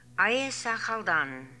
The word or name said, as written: Aiesakhaldan